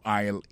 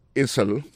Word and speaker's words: aisle: Aisle là, c'est A-I-S-L-E. Vous prononcez aisle. Il marque Essel.